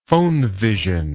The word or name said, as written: phonevision